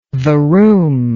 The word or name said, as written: varoom